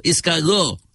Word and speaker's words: escargot: Escargot. And it's spelled E-S-C-A-R-G-O-T, not escargot. Escargot.